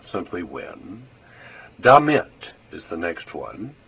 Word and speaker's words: damit: Be careful that you pronounce that with the accent on the second syllable. Damit. If you put the accent on the first syllable, it sounds like an English word that means something very different.